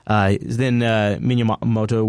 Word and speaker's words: Miyamoto: They were with an interview with how do you say his last name? Miyamoto? I can't remember. I always butcher it. – Miyamoto. – Thank you. That's what it is.